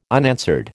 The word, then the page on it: unanswered: American English